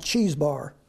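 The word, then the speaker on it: Chebar: This is, if you in verse 1, Ezekiel, it says there, he's by the river I pronounce it cheese bar.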